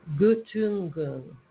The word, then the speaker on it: Göttingen: Göttingen is how it's pronounced. I mean, it's how it's the IPA says.